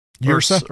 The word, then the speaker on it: Ursa: Like for example, let's talk about um uh what uh, how you say his name? Yursi Majors. – Ursa Ursa Ursa Ursa Ursa. Uh, who is um a giant bear in the Winter Guard, which is what the the uh, where the Red Guardian is from.